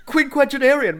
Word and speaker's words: quinquagenarian: Quinquagenarian means a 50-year-old, somebody in their 50s. – Oh, how do you say that? – That's Quinn, not Quim, by the way.